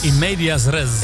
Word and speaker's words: in medias res: In Matthias Res? – In Medias Res.